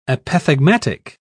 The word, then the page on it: apothegmatic: British English